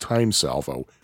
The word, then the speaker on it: T:ME Salvo: And that's actually spelled T:ME Salvo. But I verified it is pronounced Time Salvo.